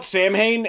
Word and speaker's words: Samhain: Also, and I'm going to bring this up every time anybody ever mentions this, it should be pronounced Samhain. Deal with it.